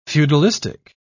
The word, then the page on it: feudalistic: British English